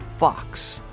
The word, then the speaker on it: Phox: Plus, an in-studio performance from the Wisconsin folk-pop band called Fox. That's spelled P-H-O-X.